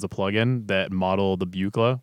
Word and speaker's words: Buchla: I think it's pronounced Buchla. – I recognize that. – Buchla.